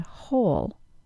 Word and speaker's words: whole: as in the words who and whole. The word whole, spelled W-H-O-L-E, is pronounced exactly the same as the word whole, H-O-L-E.